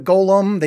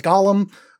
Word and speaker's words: Golem: They meet a golem. They mispronounce that as Gollum, which, that's the guy from Lord of the Rings. Golem is the Jewish weird mud monster with a skull in his mouth.